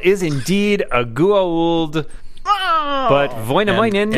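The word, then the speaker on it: Väinämöinen: It's Vainamoinen.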